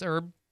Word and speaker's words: herb: Not pronouncing the H in herb. Nobody does. It's herb.